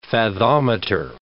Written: Fathometer

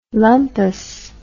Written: lumpus

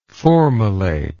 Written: formylation

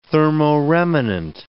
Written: thermoremanent

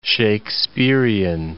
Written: Shaksperian

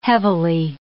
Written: heavily

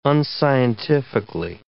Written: unscientifically

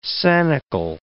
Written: sanicle

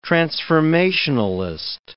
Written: transformationalist

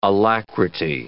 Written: alacrity

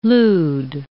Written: lewd

